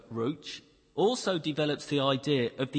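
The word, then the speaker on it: ruach (רוּחַ): The Hebrew word there that we translate as spirit all the way through the Bible is a word called ruach. R-A-U-C-H. That's how I pronounce it. My Hebrew isn't great, but ruach. And that actually means in Hebrew breath or wind.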